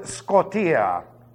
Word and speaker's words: skotia: And be careful how you pronounce this, please. It's from the Greek word skotia. That's S-K-O-T-I-A. Do not pronounce that O like an A because that means manure. It means to defecate.